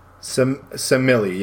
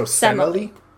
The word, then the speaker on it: Semele: There's three different E's in that, and all three are pronounced differently. Well, I guess it's like... It said, like, the first E is pronounced like the E in dress. So, Semele. – Okay. – Emily with an S at the front of it.